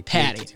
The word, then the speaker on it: Patti: It's Patti. – Patti. Okay, that's what I thought. I just wanted to make sure. – It's Sicilian, so... It's not Irish, like everybody tends to seem to think, but it ends in an I.